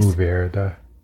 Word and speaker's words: Mourvèdre: Mourvèdre. I think you have to roll your R, but it's fine.